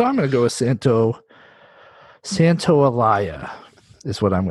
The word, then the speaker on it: Santaolalla: This is funny because I chose a song that's not actually by the composer, who is Gustavo Santaolalla, I believe is how it's pronounced. – Santaolalla, I think. – Is it? Well, then it's definitely Santaolalla. Because it's with an L and then an A and then a double L A.